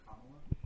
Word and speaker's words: Kamala: Kamala? – Kamala.